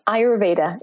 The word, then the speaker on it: Ayurveda: Ayurveda.